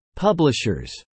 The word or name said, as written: publishers